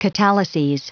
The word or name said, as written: catalyses